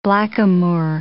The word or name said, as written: blackamoor